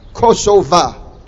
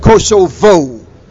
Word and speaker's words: Kosova: One of the side notes to this battle is the pronunciation of Kosova. Kosova is the Muslim pronunciation of it. On the other side, on the counter side, are those who are not Muslims, who call it Kosovo.